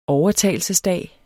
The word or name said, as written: overtagelsesdag